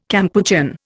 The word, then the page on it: Kampuchean: Indian